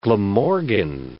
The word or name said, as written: Glamorgan